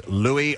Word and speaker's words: Louis: is His Royal Highness Prince Louis Arthur Charles of Cambridge. And by the way, we went over this yesterday. It is pronounced Louis. – Louis. – Not Louis.